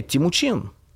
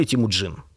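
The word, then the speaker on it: Тэмүжин: А его детское имя – Тимучин или Тимуджин. Смотря на татарском или на монгольском произносить.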